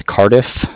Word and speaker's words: Cardiff: I'm with Cardiff. – Spell that, please. – Yeah, it's C-A-R-D-I-F-F, like Frank.